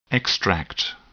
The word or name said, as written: extract